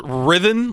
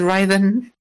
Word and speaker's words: Riven: Is Riven, like similar to rhythm, the preferred pronunciation? – Yes, that is the pronunciation I had in mind when I came up with this. But everyone says Riven. It's whatever. I don't mind.